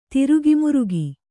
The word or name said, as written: ದುರುಗಮುರುಗಿ